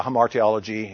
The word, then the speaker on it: hamartiology: How did you pronounce it? – I say hamartiology. – Yeah, hamartiology. Yeah, it's pronounced in different ways. Depends on how you pronounce the Greek word.